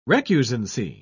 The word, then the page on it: recusancy: British English